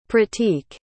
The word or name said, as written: pratik